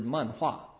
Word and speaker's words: manhua: Okay, manga is the Japanese pronunciation of the Chinese word manhua, which means cartoon.